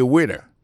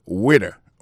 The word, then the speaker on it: waiter: I am a waiter. Ça veut dire que moi, son garçon m'a servi dans le restaurant. Alors moi, je prononce le waiter. Waiter, ça veut dire accent tombé sous le premier syllabe là. Waiter, W-A-I-T-E-R.